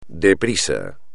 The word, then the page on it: de prisa: espagnol